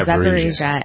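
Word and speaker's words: Zaporizhzhia: Oh, Zaporizhia, yeah. – Is that how you pronounce it? Okay, yeah, Zaporizhia.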